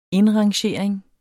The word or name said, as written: indrangering